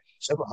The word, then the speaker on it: Chuba: Is it Chuba? Okay. – Chuba Hubbard.